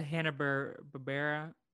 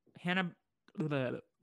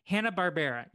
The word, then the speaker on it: Hanna-Barbera: Because it's a Hanna-Barbera. Hanna-Barbera. No, I messed it up. Hanna-Barbera. Yes, okay. I said it right the first time and then I got in my own head.